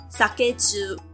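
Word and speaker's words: 提重: 所以就出现了一个很高级的东西，叫做提虫 sakeju 提呢是手提的提，重呢是轻重的那个重，但在这边它应该要读作虫会比较好。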